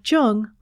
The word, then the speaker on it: Jung: You can also listen to this episode on your Amazon Echo device simply by saying ALEXA, play Speaking of Jung on Apple Podcasts. Just be sure to pronounce Jung with a hard J.